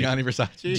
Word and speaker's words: Gianni Versace: Gianni Versace. – Gianni Versace.